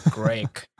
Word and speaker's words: Greak: Then we've got, I want to say Greek, but it's spelt G-R-E-A-K. Greek.